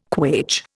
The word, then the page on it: quaich: Indian